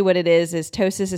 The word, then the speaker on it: ptosis: There's something called kidney ptosis.